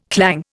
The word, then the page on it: clang: Indian